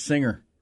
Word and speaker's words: singer: I'm like, dude, it's not a hard G. It's singer. You don't say sing.